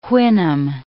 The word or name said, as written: Houyhnhnm